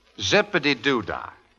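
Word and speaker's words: Zip-a-Dee-Doo-Dah: Zippity-doo-dah.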